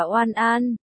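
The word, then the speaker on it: Oan An (晚安): Hửm, V-K-A-N-A-N, em yêu anh. Chấm chấm, ngủ ngon đọc là Oan An, cũng là viết tắt của vụ ai ni, cạch, đèn tắt rồi, căn phòng rơi vào màn đêm yên ắng.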